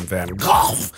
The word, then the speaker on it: Van Gogh: as it looks like you're traveling through a Van Gogh, or as you would pronounce it, Van Gogh painting, and it's beautiful.